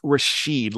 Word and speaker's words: Rashid: We've got to go with a Boston accent there. It's Hamika. And it's Rashid, like Rashid Wallace.